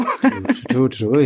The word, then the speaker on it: Turturro: I've never say his name right. Turturro. – Turturro, yeah.